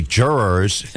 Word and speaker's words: jurors: And then, you know, instead of the word jurors, I think I may have said Jews during a newscast. – I believe jurors is actually jurors, and it's a it's a two-syllable word.